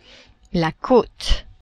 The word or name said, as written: cote